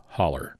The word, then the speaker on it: hollow: Also in the South, you can hear the word hollow pronounced as holler.